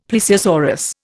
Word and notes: plesiosaurus: Indian

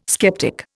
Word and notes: sceptic: Indian

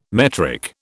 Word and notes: metric: British English

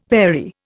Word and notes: vary: Indian

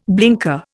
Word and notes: blinker: Indian

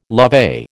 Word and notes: lovey: American English

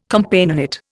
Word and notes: Companionate: Indian

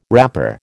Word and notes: wrapper: American English

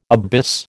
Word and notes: abyss: American English

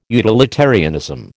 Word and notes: utilitarianism: American English

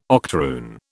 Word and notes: octoroon: British English

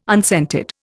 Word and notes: unscented: Indian

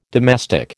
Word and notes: domestic: American English

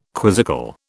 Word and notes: quizzical: British English

